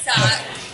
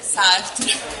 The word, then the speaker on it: Sartre: Jean-Paul Sartre, ragazzi. – Però l'hai pronunciato malissimo, Raffi, te lo dico, eh. Dillo tu, dillo tu. No, dillo tu, almeno la pronuncia giusta. Jean-Paul Sartre. Jean-Paul Sartre.